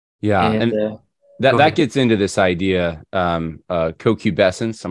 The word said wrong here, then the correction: concupiscence: I'm probably still saying it wrong. It's a new word for me. Yeah, you go for it. You say it for everyone. – Concupiscence. – Concupiscence. There we go.